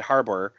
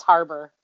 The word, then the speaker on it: Harbour: Am I saying his name right? I always say it wrong. – I just say Haba. – Haba? – Haba. – Haba. – I think it's Harbour. – It's Harbour, yeah.